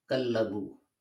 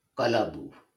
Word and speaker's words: Kalabu: And then six times it appears as Kallabu and uh, or Kalabu is better pronunciation, and five times as uh, uh, Takallub.